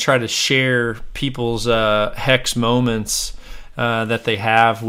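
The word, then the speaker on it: HECS: so that's Hex, that's how you spell it, H E C S L L C dot com.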